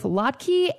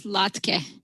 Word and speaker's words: latke: She says both latke and Lotka are American pronunciations of the original Yiddish word. – In Yiddish, the word is Lotke, with a kind of a short E. In English, we don't have that same vowel in a final syllable. Instead, we have either a or e. Another example would be chala. In Yiddish, it's chale, and in English, it could be chala or chali.